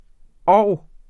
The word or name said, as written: orv